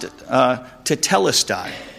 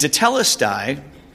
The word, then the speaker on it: tetelestai: we see a word that's pronounced tetelestai. And tetelestai, wish I had the spelling up there, but tetelestai, it means it's paid in full.